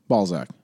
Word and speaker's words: Balzac: Balzac.